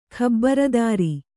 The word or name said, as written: ಖಬರದಾರಿ